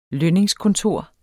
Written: lønningskontor

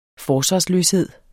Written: forsvarsløshed

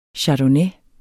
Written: chardonnay

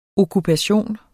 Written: okkupation

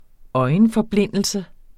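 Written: ØJENFORBLINDELSEN

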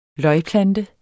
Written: løgplante

